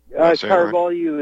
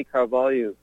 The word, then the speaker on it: Carvalho: The first one is titled "An Interview with Brazilian Writer Olavo de Carvalho." – Carvalho is how they pronounce it. Olavo de Carvalho.